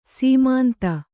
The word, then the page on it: ಸೀಮಾಂತ: sīmānta